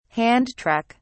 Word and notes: hand truck: British English

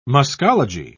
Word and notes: muscology: British English

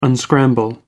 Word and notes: unscramble: American English

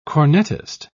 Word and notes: cornetist: British English

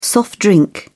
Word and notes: soft drink: British English

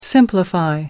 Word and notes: simplify: American English

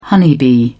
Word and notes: honeybee: British English

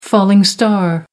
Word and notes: falling star: American English

